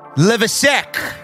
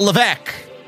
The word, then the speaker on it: Levesque: It's pronounced Levesec with an S, not Levesque.